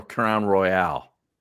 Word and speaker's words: Crown Royal: Is it Crown Royal or Crown Royale? – Crown Royal.